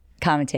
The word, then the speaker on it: Comite: And also, you can follow me if you want at Comite Center and at Florence Comite. – Can you spell that? – My name? – The Both. Comite. – Comite.